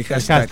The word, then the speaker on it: hashtag: Hashtag, ¿cómo se dice? – Sí, hashtag.